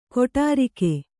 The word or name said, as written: ಕೊಠಾರಿಕೆ